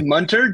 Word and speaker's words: Munter: It's Munter, just like Hunter.